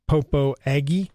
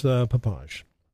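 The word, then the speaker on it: Popo Agie: Even though it looks like it's spelled Popo Aggie, and a lot of people pronounce it that way, it is Papage.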